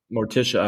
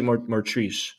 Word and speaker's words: Morticia: Morticia. I always say Martrice. Morticia.